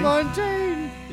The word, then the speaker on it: LaMontagne: LaMontagne? Is that how you say it? LaMontagne? – LaMontagne. – Yeah.